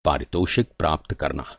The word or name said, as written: पारितोषिक